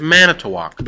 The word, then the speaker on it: Manitowoc: That's Manitowoc. Manitowoc.